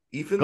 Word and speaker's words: Ifans: Am I saying that right? Ifans? – Ephens? Sounds right to me.